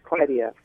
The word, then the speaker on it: Clydia: It said Clydia. – Clydia. – Yeah, it's like Clyde, except it's like Claude and Claudia, except it's Clydia.